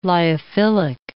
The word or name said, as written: lyophilic